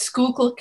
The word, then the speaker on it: Schuylkill: It's Schuylkill County. Schuylkill, it's Dutch.